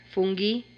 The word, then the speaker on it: fungi: Fungi? – Fungi is the Italian pronunciation.